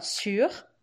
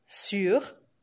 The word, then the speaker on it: sur: E se você falar sur, sur, usando o biquinho, imaginando que tem um trema nesse u, sur, sur, aí quer dizer em cima.